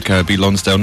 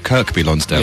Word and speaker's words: Kirkby Lonsdale: It's Kirby Lonsdale, not Kirkby Lonsdale. – Yeah. But why did you say Kirkby? – Because it's spelt Kirkby. – Right. – As in, as is the place in Merseyside spelt Kirkby, but pronounced Kirby.